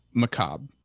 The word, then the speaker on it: macabre: This word is macabre, right? That's how you pronounce that. – Macabre. The R is like silent.